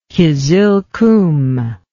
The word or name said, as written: Qizilqum